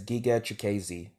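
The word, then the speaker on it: Giga Chikadze: And like you said, Cater versus, is it Ginga or Giga? – I think they say Giga. – How would you pronounce it? – I'm no expert, but from what I heard from the broadcast, they pronounced it as Giga Chikeze, the Georgian kickboxer.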